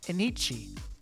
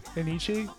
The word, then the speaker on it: Enyce: The number seven news item of 96, Anichi. The clothing label, you know that one, Anichi? – Oh, it's spelled with an E. – E-N-Y-C-E.